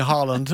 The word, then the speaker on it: Haaland: Ja, og Erling Haaland, altså det jo, vi må ta en telefon til vår kollega BBC og så si at to A-er på norsk, det uttaler vi Å.